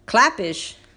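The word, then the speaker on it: Klapisch: And get this, just today, as I was recording, Bob Clappish, that's how you pronounce his name, he tweeted out, "I'm told Yankees have a 7-year, $245 million offer on the table for Gerrit Cole.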